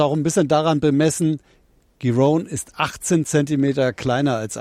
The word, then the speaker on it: Giron: Runde, weiß nicht, ob ich den Namen richtig ausgesprochen habe, aber das weiß Matthias Kammann sicherlich besser. Wie sieht's denn aus für den Hamburger? – Ja, er spricht sich tatsächlich Marcus Giron und Alexander Zverev tut sich bisher so leicht, wie man das erwartet hat.